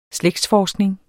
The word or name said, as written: slægtsforskning